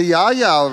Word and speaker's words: Yarra Yarra: In January of 1845, Batman's only son Charles drowned in the Yar Yar River. Eliza then... Oh, is that not how you say it? – Hang on, what did you just call it? – Yarra Yarra? – The Yarra Yarra River.